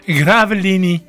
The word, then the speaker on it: Gravelines: Gravelines, dat zal wel op zijn Frans uitgesproken moeten worden. Gravelini is stilgelegd vanwege een kwallenplaag.